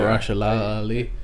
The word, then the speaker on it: Mahershala Ali: That's how you pronounce his name, right? Yeah. Mahershala Ali. – What you said, yeah.